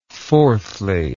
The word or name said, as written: fourthly